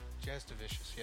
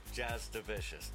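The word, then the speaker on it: Jasudavicius: Jazz Devicious. There is actually no U pronounced in it. – Right, and I'm trying to add that pronunciation.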